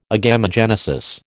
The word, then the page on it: agamogenesis: American English